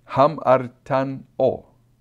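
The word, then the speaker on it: hamartano (ἁμαρτάνω): And the phonetic pronunciation is hamartano, something like that. That's how you pronounce it.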